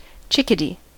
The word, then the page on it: chickadee: American English